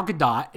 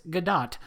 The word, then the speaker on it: Gadot: Gal Gadot, and yes, I've learned that is actually how you pronounce it. It's Gadot.